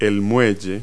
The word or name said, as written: quay